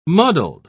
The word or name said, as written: muddled